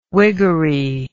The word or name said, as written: wiggery